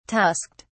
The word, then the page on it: tusked: British English